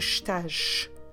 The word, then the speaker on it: estás: And when we have s at the end of the word, it's always pronounced like a sh. That's why you get the estás.